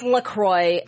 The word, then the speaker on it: LaCroix: Because that's what I, I know it's LaCroix, but, but it's LaCroix. No. LaCroix is fine Joslyn. We don't say that. And I don't fucking care what the website says. It rhymes with whatever they say, boy.